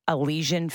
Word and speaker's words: Elysian: I think it's Elysian Field.